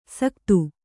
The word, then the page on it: ಸಕತ್ತು: sakattu